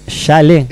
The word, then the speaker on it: chalet: Chalet. – ¿Es chalet? Ah, la T no se pronuncia.